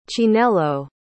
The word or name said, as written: chinelo